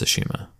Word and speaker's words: Tsushima: Tsushima. – Isn't that a PS5 game? – The T is silent. So yeah. Ignore the T and it's just Tsushima.